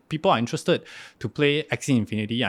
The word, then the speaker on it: Axie Infinity: Axie Infinity. A-X-I-E.